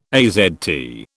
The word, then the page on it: AZT: British English